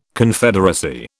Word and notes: Confederacy: British English

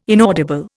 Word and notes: inaudible: Indian

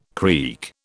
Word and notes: creak: British English

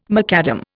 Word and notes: macadam: Indian

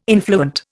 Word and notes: influent: Indian

